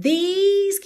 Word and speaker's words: these: In these, que rima com cheese, ok? Aquele som comprido.